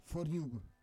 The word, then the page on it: for yuv: Roon